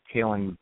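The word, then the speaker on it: Khalin: Eichner is spelled I-K-N-E-R, and actually Kaylin might be kind of difficult to spell, too. It's K-H-A-L-I-N.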